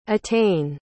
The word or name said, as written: etain